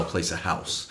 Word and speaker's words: Haus: So you pronounce it House. H-A-U-S? – Yeah, I believe it's German.